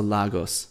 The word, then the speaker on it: Lagos: Lagos had some cool cafes with fast internet, but is it Lagos or Lagos? – Lagos. – Lagos. I've been told off for pronouncing it like that. Lagos.